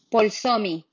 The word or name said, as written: Polsomi